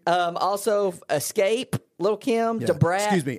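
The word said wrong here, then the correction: Xscape: I think you mean X-scape. – X-scape. I didn't put enough emphasis on the X.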